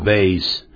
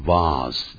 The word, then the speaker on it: vase: Vase 就是花瓶，但也有听到人家念成 vase 这样。